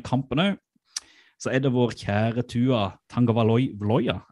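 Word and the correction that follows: Tagovailoa: Er det sånn man uttaler det? – Tangavailoa. – Tangavailoa.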